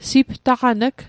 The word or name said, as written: siptaqan'g